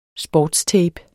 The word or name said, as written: sportstape